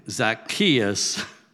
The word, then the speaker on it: Zacchaeus: Zacchaeus.